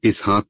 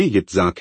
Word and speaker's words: यित्जाक: यित्जाक का अर्थ है हसी और आप लोग इसका उच्चारण इसहाक करते हैं, लेकिन यह वास्तव में यित्जाक है।